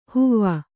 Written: ಹೂವು

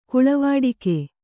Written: ಗಾಳಿವಟ್ಟೆಗ

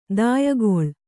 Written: ದಾಯಗೊಳ್

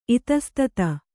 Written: ಇತಸ್ತತ

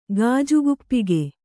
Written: ಗಾಜುಗುಪ್ಪಿಗೆ

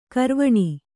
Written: ಗೌರೀವೀಣೆ

